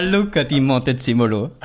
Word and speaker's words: Luca di Montezemolo: Hast du das jetzt überhaupt richtig ausgesprochen? Spricht man das so aus? – Ja, würde ich auch so machen. Ich meine, wir können zur Sicherheit noch einen anrufen, aber der Luca di Montezemolo.